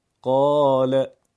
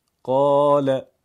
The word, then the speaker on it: قال: نکته مهم دیگر اینه که الف گاهی در کلمات قرآن کوچک نوشته میشه، مثل قال، قال. تلفظ الف کوچک با الف بلند هیچ تفاوتی با هم نداره.